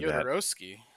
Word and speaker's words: Jodorowsky: So I think like the obvious choice is Jodorowsky, and I know I'm mispronouncing Jodorowsky.